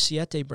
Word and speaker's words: Siete: Like Lowe's Foods carries Siete brand now. And listeners that haven't heard previous episodes, Siete, spelled like the number 7 in Spanish, they make a wheat, grain, and corn-free tortilla.